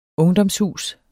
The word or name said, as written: ungdomshus